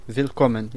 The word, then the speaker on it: Willkommen: Willkommen, yeah. – Willkommen, yeah. Right, the W, pronouncing a V, right.